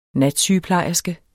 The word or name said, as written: natsygeplejerske